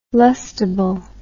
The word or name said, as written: lustable